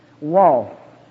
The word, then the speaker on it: waw: Then we come to remember in the next section, under wall, as you see in the King James Version there, V-A-U. It was told me in seminary it's better pronounced W-A-W, wall.